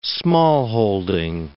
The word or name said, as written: smallholding